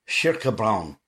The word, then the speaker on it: Kirkebrann: And I think what he said was Kirkebrand, but it's spelled Kirkebrann, K I R K E B R A N N, which is Norwegian for church burning.